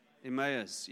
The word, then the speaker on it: Emmaus: Emmaus, yes.